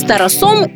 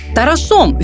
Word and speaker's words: Тарасом: Для мене досі звучить дивно, коли кажуть: ми з Тарасом ідемо на каву. Чому Тарасом? Тарасом він Тарас, і наголос нікуди з цієї а не втікає.